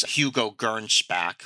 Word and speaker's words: Hugo Gernsback: I want to say it correctly, which is Hugo Gernsback, who was from Luxembourg.